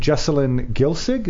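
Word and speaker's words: Jessalyn Gilsig: She is very active in casting independent features, most recently Somewhere Slow, starring Jesselyn Gilsig.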